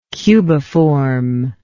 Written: cubiform

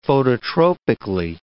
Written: phototropically

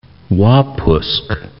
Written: Wapusk National Park

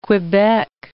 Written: Quebec

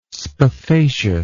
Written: spathaceous

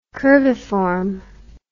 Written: curviform

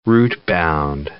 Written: root-bound

